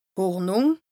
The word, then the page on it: Hornùng: Bas-Rhin; Strasbourg